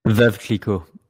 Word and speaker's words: Veuve Clicquot: Yeah, the Veuve Clicquot or whatever that. I'll let pronounce that in French. Since now that I know he majored in French in college, Veuve Clicquot.